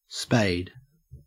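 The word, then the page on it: spade: au Australian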